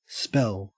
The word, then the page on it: spell: Australian English